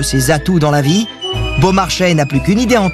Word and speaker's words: Beaumarchais: le domaine du Beaumarchais, qu'on prononce Beaumarchais.